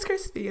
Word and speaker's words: Chrispy: And my name is Chrispy, and that's spelled C H R I S P.